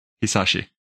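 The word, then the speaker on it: Hisaishi: Yo Hiyashi eller vad man nu uttalar det. – Hisashi. – Och det är rätt.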